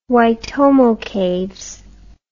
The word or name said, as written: Waitomo Caves